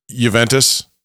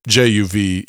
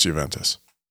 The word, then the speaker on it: Juventus: Juventus? – What am I thinking of? – Well, it's Juventus. – So I'm literally just pronouncing it wrong. – Yeah, it's pronounced Juventus.